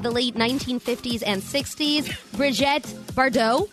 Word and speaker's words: Brigitte Bardot: Brigitte Bardot.